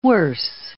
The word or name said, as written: worse